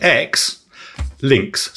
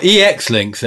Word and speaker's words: Xlinks: A company called Xlinks, which is spelt X, links, so that's quite easy to say. – But it's not Exlinks, though, is it? It's not former links. – No. It's just X. No. It's Xlinks. Xlinks.